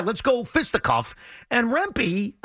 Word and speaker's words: Rempe: This Mike Rempe, is that how you say his name? – Yes, Rempe. Rempe, who's about 8 feet tall.